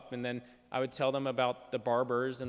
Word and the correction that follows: Berbers: and they'd say it's Berbers, you know, correct my pronunciation.